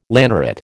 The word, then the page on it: lanneret: American English